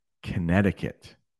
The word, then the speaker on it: Connecticut: Daniel is from the state of Connecticut.